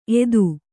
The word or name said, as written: ಏತ